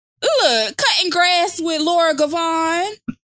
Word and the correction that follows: Govan: Govan, I have to fucking say her name.